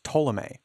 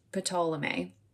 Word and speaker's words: Ptolemy: I just finally want to make note that I read this as Ptolemy. Like, for sure, use the P. Like, just no questions asked. – I have to keep myself from saying Ptolemy because it's fun. I feel like it's kind of fun. – Idiot. It is. But I obviously the P silent and I fully was like, no, I think it's Ptolemy. Yikes. – I'm sure that it's not entirely silent.